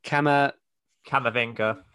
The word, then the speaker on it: Camavinga: The is it Camavinga? – Yeah, thank you.